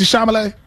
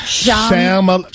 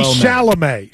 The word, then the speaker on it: Chalamet: Shama. It's a lot of like M's and L's in his name. – You never once managed in that segment to say it correctly. You said sham, you said charm, you said...